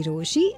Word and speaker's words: Rochie: मेरा नाम है मिर्ची रोशी, नाम की स्पेलिंग है ROCHIE.